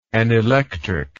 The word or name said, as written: anelectric